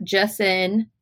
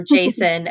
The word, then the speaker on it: Jacin: Scarlet was captured by Sybil, and Cinder is left with an injured and unconscious Wolf and the Lunar Guard, Jason, as I say, because I say it wrong, or Jason, as everybody else says.